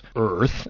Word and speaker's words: Eaarth: I'm going to read Bill McKibben's latest book, which he calls Earth, and I have to prolong the pronunciation of that. He says you should say it as though you were Arnold Schwarzenegger. – Because it's spelled E-A-A-R-T-H.